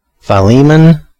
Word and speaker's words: Philemon: So, it appears that the correct pronunciation would be Philemon, with a long I at the beginning, Philemon or Philemon. Either way, wouldn't be Philemon like I was saying, most likely.